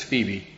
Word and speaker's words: Phoebe: Today's class is on, and I'll pronounce it as Phoebe, however you want to pronounce it, P-H-E-B-E or P-H-O-E-B-E, Phoebe.